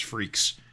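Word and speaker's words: Freex: I guess I didn't realize it's pronounced Freaks.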